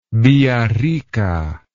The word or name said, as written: villarrica